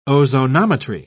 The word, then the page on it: ozonometry: British English